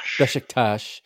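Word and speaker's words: Beşiktaş: Besiktas. I'm probably saying that wrong. – Besiktas.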